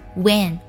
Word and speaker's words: when: 我们在读这句话的时候注意一下 when 这个单词要读作问题的问，这里的字母 e 发的是 a 的音， when 加鼻音 when。